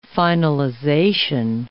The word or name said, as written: finalization